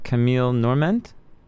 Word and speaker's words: Camille Norment: And some of them, if I'm pronouncing her name right, Camille Norment? – Right.